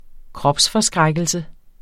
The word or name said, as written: kropsforskrækkelse